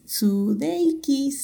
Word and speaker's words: Sudeikis: Esta serie está desarrollada por Bill Lawrence, Jason Sudeikis, Sudeikis, sí, así, así se pronuncia, claro.